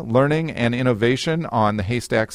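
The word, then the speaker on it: HASTAC: She's the co-founder of Humanities, Arts, Science, and Technology Advanced Collaboration, and that is an abbreviated, that's pronounced Haystack.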